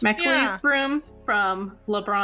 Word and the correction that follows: Lebanon: Did I say that town name correct too? Hello. – No. It's Lebanon.